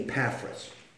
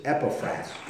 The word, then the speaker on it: Epaphras: And I say Epaphras. The other acceptable pronunciation is Epaphras. And so you can pronounce it however you will.